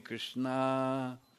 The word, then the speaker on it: Krishna: With a long A, Krishna.